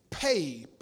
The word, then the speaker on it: pey: It's spelled P-E or some places P-E-Y, but it's pronounced P-A-Y.